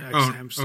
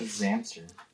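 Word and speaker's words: xHamster: X Hamster. – Oh, okay, all right. – Oh, that's how you say it. I just heard Zamster.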